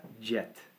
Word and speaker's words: Djet: The Matra Jet. I think that's pronounced right. It's DJET. – Right.